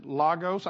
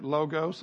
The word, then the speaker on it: logos: Now, that's some people pronounce this logos. I pronounce it logos. That may be West Texas pronunciation.